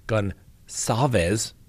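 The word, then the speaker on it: Goncalves: And they are denying telling the family of victim Kaylee Gonsalves, that's right, soft C apparently, almost sounds like an S, that her injuries were more brutal than those of other victims.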